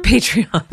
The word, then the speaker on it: Patreon: Or maybe for her Patron, or am I saying it right? Patreon? – Patreon. – Patron. I'm thinking of tequila.